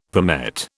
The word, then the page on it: palmette: British English